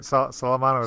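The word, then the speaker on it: Salamano: Was that right? Was it Sala? – That was right. Salomano.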